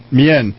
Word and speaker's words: Mien: Okay, Sue Seychelles is the first Mien, is that right? – Mien.